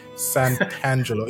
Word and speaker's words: Santangelo: Santangelo.